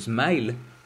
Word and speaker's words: SMIL: Y actualmente encontramos ejemplos, por ejemplo, SMILE, que se escribe SMIL, se usa para crear presentaciones multimedia.